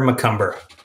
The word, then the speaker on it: McCumber: Yeah, so my name is Parker McCumber.